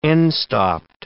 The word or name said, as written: end-stopped